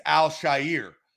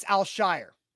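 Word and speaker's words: Al-Shaair: he's saying it's Al Shire. How are you pronouncing his last name? – It's Al Shire. – Well, I will put out the phonetic spelling of what they said. I believe it is Al Shire.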